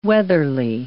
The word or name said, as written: weatherly